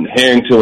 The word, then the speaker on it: Harrington: It's V-I-L-A-I and then Harrington with an A, H-A-R-R-I-N-G-T-O-N.